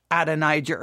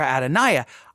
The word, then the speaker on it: Adonijah: Like, why would we drop the J or make the J silent and be Adoniah? Now, he's going to offer an explanation for why he says you could pronounce it, I guess, either way, Adonijah or Adoniah.